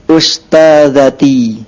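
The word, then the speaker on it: أستاذتي: Shin wala sin? – Sin. Ustazati. – Terdengar shin antum bacanya. Us. – Us. – Ah, adawa. – Ummuhunna ustazati. – Ahsant.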